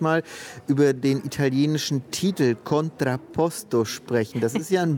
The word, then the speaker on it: Contrapposto: Ja, du hast ihn auch so wunderschön italienisch gerade ausgesprochen, Contrapposto.